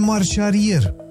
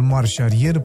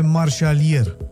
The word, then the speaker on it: marșarier: Am tot auzit în ultima vreme cuvântul marșarier pronunțat greșit și anume marșalier. Mai mult decât atât, l-am și văzut scris greșit în anunțuri sau chiar în titluri de articole. Confuzia vine de la sunetele R și L deseori încurcate în limba română.